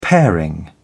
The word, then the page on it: paring: British English